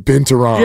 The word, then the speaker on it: binturong: But Dan Binturong. – Yeah. – Emphasis on wrong, am I right? – Yeah.